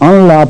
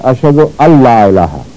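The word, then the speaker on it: أن لا: اللہ اشد اللہ الہ الا اللہ، جو لوگ ان لا پڑھتے ہیں وہ غلط پڑھتے ہیں، کیونکہ صحیح تلفظ ہے اشد اللہ الہ، لیکن نون لکھا جاتا ہے ویسے.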